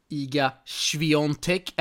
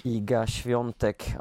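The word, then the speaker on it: Iga Świątek: Eh, någonting som inte var lika kul var Rebecca Peterssons förlust där mot Iga Swiatek. Är det rätt uttal, du som är halvpolack? Iga Swiatek, ja det är faktiskt rätt uttal och, eh, och den matchen såg jag däremot i, i sin helhet för den gick ju under kvällen.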